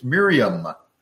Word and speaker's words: Miriam: It's interesting in the original, it's Miriam, as an M on the end. Luke gave her Hebrew pronunciation there. All through this narrative, he calls her Miriam. That was her, her name. Uh, in English, we just say Mary, so it's not a mistranslation.